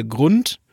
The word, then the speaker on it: Grund: Aber ja, wenn ihr da durch seid, dann könnt ihr auch nochmal in den Stadtteil Grund ja, heißt es, heißt wie die wie der Grund, wie der, wie der Boden eines Flusses, ähm, schreibt man den und das ist ein bisschen, ein bisschen entspannter vielleicht.